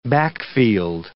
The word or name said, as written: backfield